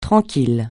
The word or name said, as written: tranquille